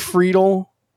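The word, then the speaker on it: Friedl: TJ Friedel, assuming that's how you pronounce his name.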